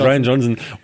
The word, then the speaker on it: Rian Johnson: Die schreibt man nicht Ryan, schreibt man I-R-I-A-N. Also nicht aber der heißt trotzdem Ryan Johnson.